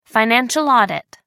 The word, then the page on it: financial audit: American English